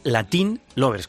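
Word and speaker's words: Latín Lovers: Bueno, el subtítulo es, el título es Latin Lovers, con acento en la I, que juega, con tilde en la I, que juega con lo de Latin Lover, ¿no?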